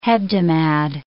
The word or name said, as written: hebdomad